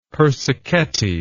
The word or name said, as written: persichetti